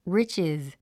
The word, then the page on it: riches: rítʃiz; リッチィズ